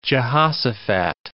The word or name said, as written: Jehoshaphat